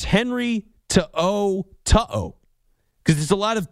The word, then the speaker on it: To'o: Henry To'o, which you can hear the difference. Like, Coyote, Coyote. To-o, to-o.